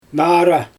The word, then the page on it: naara: Xyzyl